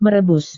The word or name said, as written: merebus